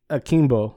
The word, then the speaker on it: Akimbo: So the next one is, okay, you gotta help me with the pronunciation. What was it? Guns Akimbo? – Akimbo. – Akimbo. – Akimbo. – Yes. – A-K-I-M-B-O.